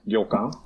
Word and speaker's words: ryokan: Någonting som är väldigt känt, som jag som inte har varit i Japan känner till, är det som i alla fall stavas ryokan. Uttalar jag det rätt? – Ja. Ryokan. – Ryokan.